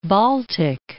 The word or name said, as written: Baltic Sea